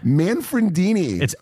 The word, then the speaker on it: Manfredini: How do you say his name? – Manfredini. It's Manfredini.